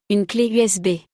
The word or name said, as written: Une cle USB